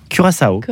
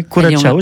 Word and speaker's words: Curaçao: Comment on prononce ça? – Curaçao. – Ah oui, Curaçao, j'aime bien.